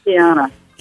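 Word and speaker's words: Quiana: Spelled with a Q-U-I-A-N-A. It's Kiana.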